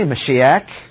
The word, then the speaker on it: Mashiach: Pronounced a little bit differently, Mashiach.